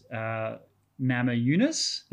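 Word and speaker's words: Namajūnas: Also, I was reminded that the pronunciation is Nama Yunus. The J, so Rose Nama, I was saying Rose Nama Yunus because I just read the words phonetically. – Yeah. – But in Lithuanian, the J is silent. – J is silent, yeah. So thanks for that. – Or J is Y.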